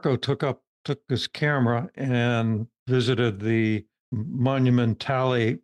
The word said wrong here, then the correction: Monumentale: no Monumentale Cemetery of Milan. He's he's helping me pronounce Italian properly it's hard for hard for a gringo, you know, especially a hillbilly, to uh, pronounce their language properly.